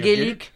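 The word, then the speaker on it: Geluck: Oui, non, c'est Geluc. – C'est Geluc. – Alors, c'est Geluc. – Ouais, mais ouais, comme ça s'écrit GE.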